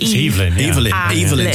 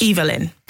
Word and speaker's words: Evelyn: And then she said, sorry, everyone pronounces it wrong, so I have to be safe that you get it right. So she's put Evelyn. So 3 syllables. – Evelyn.